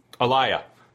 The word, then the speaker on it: Aaliyah: I was going to say we should ask Wade about how do you say Aaliyah, Wade? – Aaliyah. – Yeah, thanks. Like, you like her knee, so Lee. You like her knee, so there you go.